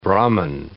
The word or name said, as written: Brahmin